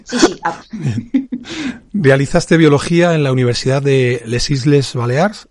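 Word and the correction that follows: Illes Balears: No sé si lo he dicho bien. ¿Bien? – Sí, Illes Baleares Perfecto.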